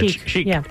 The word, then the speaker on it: Chic: Do you pronounce this? Is it Chic? – Yeah, Chic.